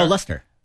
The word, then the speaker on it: Leicester: Oh, Leicester.